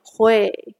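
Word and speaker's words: 會: We like to say it with a smile because when you pronounce hui, you need to smile. Let's pronounce it again. Hui.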